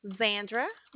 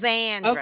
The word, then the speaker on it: Zandra: Zandra. – Your name? Zandra or Aunt? Go ahead. Spell it for me. – it's just like Sandra, but with a Z. – Okay. – Zandra.